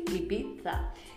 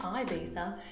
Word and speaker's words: Ibiza: Actually, Ibiza is how you say it, not Ibiza, it's Ibiza.